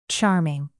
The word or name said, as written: charming